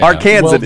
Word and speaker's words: Arkansan: Arkansan is the main term today.